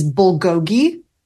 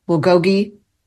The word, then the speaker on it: bulgogi: The second one is bulgogi. B-U-L-G-O-G-I, bulgogi. Number three is ackee and saltfish, A-C-K-E-E, and saltfish.